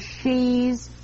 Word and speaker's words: ski: If a shipment of Norwegian ski sweaters married a shipment of Norwegian ski pants, would all their children be she's? – Oh, cut it out now, will you? – Don't you get it, dear? In Norwegian, ski is pronounced she.